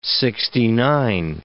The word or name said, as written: sixty-nine